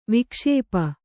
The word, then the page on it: ವಿಕ್ಷೇಪ: vikṣēpa